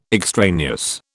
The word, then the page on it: extraneous: British English